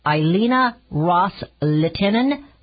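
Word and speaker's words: Ileana Ros-Lehtinen: Elena Rose Latinen from Florida. I'm sure I didn't pronounce that right. It's Elena Ross Latinen from Florida.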